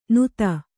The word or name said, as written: ನುತ್ತ